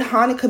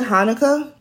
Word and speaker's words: Hanukkah: And I've learned over the years that it's pronounced with a ch, like that guttural sound. I call it Hanukkah because that's how I understand it.